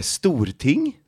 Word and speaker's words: Storting: It makes sense when if you say it like in Norwegian, like a Storting, then I get it like, yeah, okay, yeah.